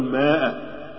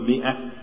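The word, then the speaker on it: مائة: ماء، هو طبعا أنا شايف ألف، هو بيشوف ألف مكتوب ألف، احنا قلنا أن هذه الألف لا تنطق، قل مئة يعني مئة وليست ماء.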